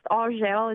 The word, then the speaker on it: Argel: It's pronounced Argel. It's French.